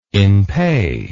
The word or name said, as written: in pais